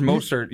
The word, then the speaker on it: Mostert: It's Mostert. You just butchered his name.